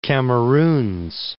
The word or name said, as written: Cameroons